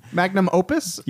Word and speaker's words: Magnum Opus: The Magnus Opum? – Yeah. The Magnus Opum. – I pronounced that completely wrong. – Magnum Opus? – Yeah, that.